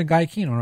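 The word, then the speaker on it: Giacchino: Are we saying his name wrong again? – It was Gianchino, right? – Okay.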